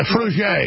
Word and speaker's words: Frugier: So I want to send it to Tim Frug. Or I don't know if I'm pronouncing it. Yeah, Frugier. It's a French name. Frugier.